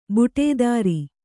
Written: ಬುಟೇದಾರಿ

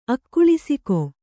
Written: ಅಕ್ಕುಳಿಸಿಕೊ

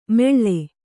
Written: ಮೆಳ್ಳೆ

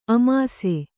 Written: ಆಮಿಸ

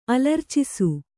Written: ಅಲರ್ಚಿಸು

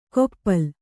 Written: ಕೊಪ್ಪಲ್

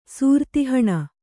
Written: ಸೂರ್ತಿಹಣ

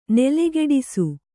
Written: ನೆಲಗಟ್ಟಿಸು

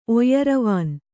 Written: ಒಯ್ಯರವಾನ್

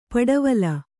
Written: ಪಿಡಿವಲೆ

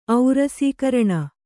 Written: ಔರಸೀಕರಣ

